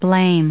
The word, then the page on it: blame: American English